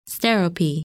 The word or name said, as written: Sterope